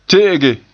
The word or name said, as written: te'ege